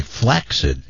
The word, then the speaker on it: flaccid: Although, if you listen regularly, you'd know that recently we educated everyone that the preferred pronunciation is actually flaccid. And we didn't. Nobody knew that. – Flaccid. – Yeah, your flaccid member. But it is acceptable to say flaccid member or just limp.